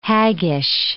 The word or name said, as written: haggish